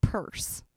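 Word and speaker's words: Peirce: Charles, yeah, but it's pronounced Purse. So Charles Purse.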